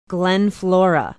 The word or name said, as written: glen flora